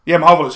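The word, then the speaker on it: Mahovlich: Well, they said he's the first guy since fucking Frank Mavlich. I can't pronounce his name in that. – Mavlich.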